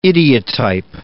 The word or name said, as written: idiotype